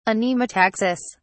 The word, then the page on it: anemotaxis: British English